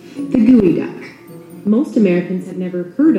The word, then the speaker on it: geoduck: It may be spelled geoduck, but it's pronounced goey duck.